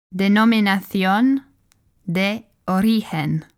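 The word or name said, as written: Denominación de Origen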